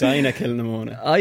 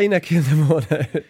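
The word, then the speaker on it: Inagh-Kilnamona: It's Ina Kilnamona. – Ina Kilnamona.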